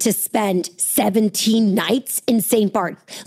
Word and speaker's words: St. Bart's: It is St. Bart's. It's how it's said. Like, Bart. Bart Simpson. St. Bart's.